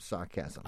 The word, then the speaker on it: sarchasm: Sarcasm, but it's spelled S-A-R-C-H-A-S-M, sarcasm.